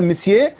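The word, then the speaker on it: monsieur: الخط ده قديم جدا، يمكن في يوم كان بيتكتب كده، لكن احنا بنتكلم دلوقتي اللي يكتب مثلا مسيه يلاقيها كلمة قد كده اهو، وهي في الاخر نطقها ثلاث اصوات اللي نطقها، لان مسيه فيها ان مش منطوقة.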